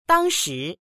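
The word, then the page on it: dang1shi2: Mandarin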